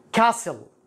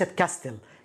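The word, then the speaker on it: castle: كاسل كاسل وليست كاستل كاسل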